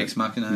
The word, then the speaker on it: Ex Machina: Ex Machina.